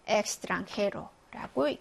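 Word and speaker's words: extranjero: 얘는 크스 발음입니다. 엑스트랑헤로라고 읽죠.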